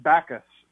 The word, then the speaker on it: Backues: Yeah, I usually it's just Backues because of we try to make it complicated by putting weird spelling in, but Backues is good.